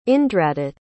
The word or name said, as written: indradutt